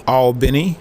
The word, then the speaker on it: Albany: I am in Albany, Georgia, or Albany, as they say.